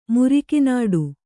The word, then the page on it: ಮುರಿಕಿನಾಡು: muriki nāḍu